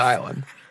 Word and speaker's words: Isla: Ilsa Nublar. – It sounds like a scientist's name. – I believe it's Ilsa Fisher. Is that how you say her name? – Isla. – Wow. Then it's Isla Nublar.